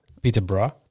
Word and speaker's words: Peterborough: Zo had ik het uitgesproken, maar het is dus, ja, Peterborough.